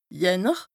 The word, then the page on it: Jänner: Bas-Rhin; Strasbourg